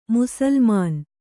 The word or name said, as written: ಮುಸುಲುಮಾನ